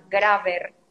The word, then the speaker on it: Grabr: Algo que yo uso mucho es una aplicación que se llama Graver. Se escribe G-R-A-B-R.